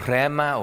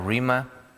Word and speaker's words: rhema: There's a second and equally commonly used word called rhema or rhema, rhema, depending on your pronunciation.